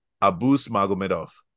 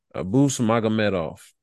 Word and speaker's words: Abus Magomedov: So our main event, we had Sean Strickland against how do you say it? Abus? Abus Magomedov. Abus Magomedov.